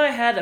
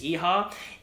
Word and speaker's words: Iha: You and I had a really bitter argument over how to pronounce James Iha. If he's Japanese, the pronunciation is Iha, and if he says Iha, he's pronouncing his own name like a white person.